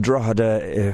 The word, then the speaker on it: Drogheda: Or Drogheda, D-R-A-W-D-A, as I like to pronounce it. But it's pronounced Drogheda if you're a posh person. That's Drogheda.